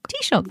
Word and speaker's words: Taoiseach: I'm going to try and pronounce this. Taoiseach. – It's a Taoiseach. – Taoiseach. – Taoiseach. That's right. That's how to say it, isn't it? – Yeah.